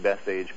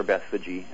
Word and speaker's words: Bethphage: But I do think that it's quite likely that he'd been in Bethphage before, because, of course, or Bethphage, depends on how you want to pronounce it, but it's likely pretty close to Bethany, and it's likely fairly close to the house of Lazarus.